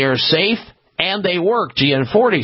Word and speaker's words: Gianforte: Montana's Governor Greg Gianforte, or Gianforte is how you pronounce his last name.